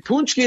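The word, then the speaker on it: pączki: P-A-C-Z-K-I. Of course, that's how you spell it.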